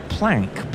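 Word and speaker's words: Planché: But Weber's librettist was the up-and-coming writer James Robinson Planchet, apparently pronounced Plank by the insular English.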